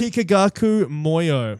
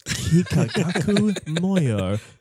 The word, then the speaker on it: Kikagaku Moyo: Kikagaku Moyo. – Can you spell it? One more time. – Kikagaku Moyo.